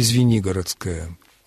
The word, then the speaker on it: Звенигородская: Попробуйте произнести «Звенигородская». У вас окажется ударение на втором слоге, а потом посчитайте, сколько будет безударных слогов. В многосложных словах русского языка общая тенденция сводится к тому, что ударение стремится занять срединное положение в слове.